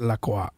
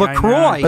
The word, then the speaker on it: LaCroix: It's pronounced LaCroix. – By the way. – I know. It's not really a French company. They're from the United States. – They're from Wisconsin. – LaCroix.